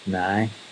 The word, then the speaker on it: now: If you're from Belfast, nearly every sentence will end with now, only it's pronounced nigh.